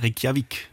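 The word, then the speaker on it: Reykjavík: Reykjavik. – Reykjavik.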